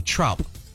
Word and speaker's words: Traup: His last name is pronounced, it's spelled T-R-A-U-P, Trump.